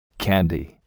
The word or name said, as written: candy